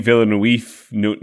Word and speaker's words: Villeneuve: This is the second film adaption directed by Denis Villeneuve. I desperately tried to figure out how to say Villeneuve.